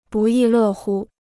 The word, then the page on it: 不亦乐乎: bù yì lè hū